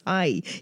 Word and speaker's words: hej: So the Danish for hello or hi is H-E-J. But I had said H, but it's hi. You pronounce it hi, apparently. – Oh, it's a silent J.